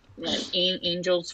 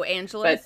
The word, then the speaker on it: Angeles: And they went to this sleepaway camp, and it was, like, an hour and a half away from here up in, like, Angels Forest or Angelus Forest. I don't know how to say it. – Oh, Angelus. – But there it is. Angelus Forest. You know, like, oh, yeah, like Los Angeles.